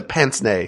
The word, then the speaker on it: pince-nez: The pince-nez, because you can turn it the over. What? – The pince-nez. – Oh, is it? Wait, you don't pronounce the Z at the end of that?